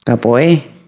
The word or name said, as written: protzen